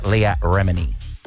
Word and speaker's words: Remini: And there's two newcomers, Tracy Tudor, formerly from Million Dollar Listing, and then we've debated how to pronounce this a long time, actress Leah Remini. – Yes, I believe you've said that correctly.